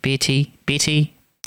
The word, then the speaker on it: Beattie: Pretty sure that's how he told me to pronounce it, Beatty. But anyways, it's spelt B-E-A-T-T-I-E.